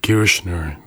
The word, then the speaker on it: Kirchner: Ο συνθέτης, ο Kirchner ή Kirchner, στην Αμερικάνικη προφορά, ο ίδιος πίστευε πως η αμιγώς ηλεκτρονική μουσική οδηγεί τον ακροατή τελικά στην ανία.